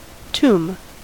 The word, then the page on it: tomb: American English